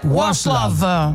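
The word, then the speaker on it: Václav: Da, tra l'altro ti diciamo che noi abbiamo trovato la soluzione alla pronuncia, sì. – Si dice Waslov.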